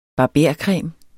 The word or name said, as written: barbercreme